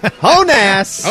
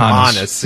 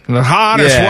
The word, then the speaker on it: Honus: Honass.